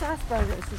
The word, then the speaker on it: Asperger's: He has Asperger's, is that how you say it? – Yeah.